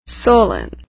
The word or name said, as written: solon